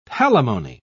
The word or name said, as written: palimony